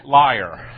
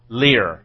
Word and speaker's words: lyre: How do you pronounce L-Y-R-E? I'm just interesting regionally. How do you pronounce that? Any thoughts? Who say liar? Okay, who say lear? – Lear.